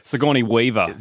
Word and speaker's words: Sigourney Weaver: Sigourney Weaver.